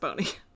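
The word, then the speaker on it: Boni: B-O-N-I. I'm assuming it's Boney. It could be Bonnie. – I would assume so. – But in American grammar, that is pronounced Boney.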